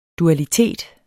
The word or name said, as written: dualitet